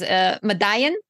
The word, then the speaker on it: Madayen: So the film that you chose is Madayen. Am I pronouncing that correctly? – Yeah.